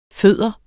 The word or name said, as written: fode